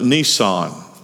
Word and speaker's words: Nisan: It's the month of Nisan, not the car, but the month. It's spelt two ways, N-I-S-A-N, N-I-S-S-A-N.